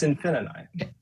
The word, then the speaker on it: Infininight: It's Infininight. So there's actually like an N there.